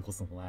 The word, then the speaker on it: 秋桜: 秋に桜と書いてコスモスはいいですね。